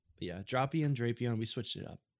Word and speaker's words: Drapion: For people that don't know, it's pronounced Dropion, not Drapion.